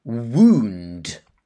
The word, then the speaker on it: wound: H-E-A-L. And the second word, also in the headline, wound. W-O-U-N-D. And pay attention to the pronunciation. Wound. So it's oo, not wound, but wound. Okay, next we have another noun. Cheek. C-H-E-E-K. Cheek. And then a verb. Chew. C-H-E-W.